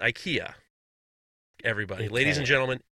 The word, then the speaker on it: IKEA: The proper pronunciation of IKEA is actually IKEA.